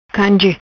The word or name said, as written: Canje